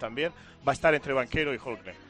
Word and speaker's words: Banchero: Bueno, en Duke se ha ido ahora un jugador excepcional que se llama Paolo Banchero, que os sonará italiano y es porque es... – Banquero. – Banchero, se escribe.